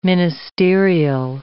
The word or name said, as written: ministerial